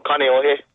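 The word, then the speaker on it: Kaneohe (Kāneʻohe): All right, how do you pronounce where you live in Hawaii? – Kaneohe. – Okay, you said it better.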